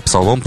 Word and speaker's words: псалом: Или псалом, правильно, как ударение из Библии.